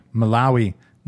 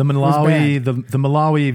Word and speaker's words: Malawi: Malawi. – Malawi.